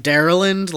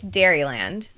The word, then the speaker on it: Dairyland: It's not like it's not like Daryland, like Maryland. – No, no, Dairyland? it's Dairyland.